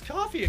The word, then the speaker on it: Ko-fi: It's actually pronounced coffee.